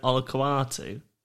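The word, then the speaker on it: Olakau'atu: Hamol Olakatua. Wait, what about that? – I thought, is it Olakuatu? That's how I hear him say it. – Okay, we're going to go with Olakuatu.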